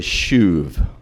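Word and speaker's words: shuv (שוב): In Hebrew, the word for turn, return, restore is shuv. You could spell it S-H-U-V or U-V-E, shuv.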